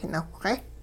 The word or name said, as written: nawhxe